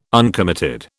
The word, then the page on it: uncommitted: British English